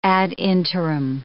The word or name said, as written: ad interim